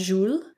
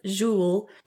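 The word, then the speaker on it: Julez: I believe it's a French name for the kid, J-U-L-E-Z. I think it's pronounced Jules.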